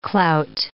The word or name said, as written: clout